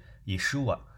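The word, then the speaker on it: 以 书 瓦 (ישוע): 那约书亚啊很有意思，他和耶稣啊约书亚和耶稣这两个名字在希伯来文里是一个名字啊，发音是一样的，都是以书瓦。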